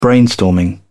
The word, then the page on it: brainstorming: British English